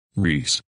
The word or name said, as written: Rhys